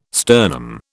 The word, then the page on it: sternum: British English